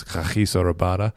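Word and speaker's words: Kagiso Rabada: And their one really stream and express fast bowler is Khachiso Rabada. That's how you say it. Neil Manthorpe taught us that.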